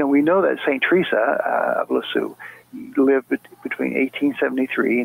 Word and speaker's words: Teresa: Now, some people call her Saint Therese, but that's more of a French pronunciation of her name. But in English, of course, we call her Saint Teresa.